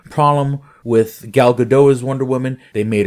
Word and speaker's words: Gal Gadot: because I guess that's apparently the proper way to say it, Gal Gadot, and I photoshopped like a very bad Wonder Woman outfit on her, and of course that's when she was a lot thinner.